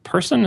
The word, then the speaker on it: Persson: So Marcus Persson, is that how you pronounce his name? P-E-R-S-S-O-N? – Yes.